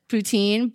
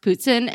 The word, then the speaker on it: poutine: Apparently in America we pronounce it poutine, but my friend who's from Quebec said it's pronounced poutine, and now I'm not allowed to pronounce it any differently, otherwise she'll kill me.